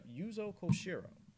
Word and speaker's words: Yuzo Koshiro: That was The Palace of Destruction from Ys 1 and 2 Chronicles, made by none other than the Falcom sound team and Yuzo Koshiro. I believe I said his name right. Let me double check that real quick. Yep, Yuzo Koshiro.